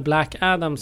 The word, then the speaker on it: Black Adam: Ja, jag var och såg Black Adam. – Mm. Eh, eller Black Adam som den mer korrekt eh, uttalas.